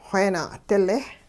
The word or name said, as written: xweena'dile